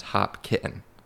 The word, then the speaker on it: Hopkinton: So his town is Hopkinton, but it's pronounced Hop Kitten, like Hop and Kitten, the animal.